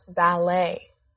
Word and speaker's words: ballet: This word is pronounced ballet. – Ballet? But there's a T at the end. – Yeah, there is, but it's silent. That's because the word came from French. In French, if you see E-T at the end of a word, it is pronounced A.